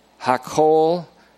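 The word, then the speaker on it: hakol (הכל): The Hebrew is hakol, H-A-K-K-O-L, hakol chesib David. In the phonetic spelling. Hakol chesib David, as you would pronounce it.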